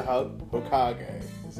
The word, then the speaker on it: Hokage: He's a Hokage. Did I say it correctly? – You did say it correctly.